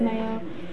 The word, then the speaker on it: Mayo Clinic: Mayo sounds like mayonnaise, but it is Mayo.